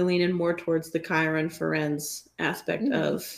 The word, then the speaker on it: Firenze: But there is one, Ferenz, I'm going to butcher his name. I don't remember if it's Ferenz or if it's Fierenz or if it's Fierenez or whatever. – Yeah. Firenze. We all love him.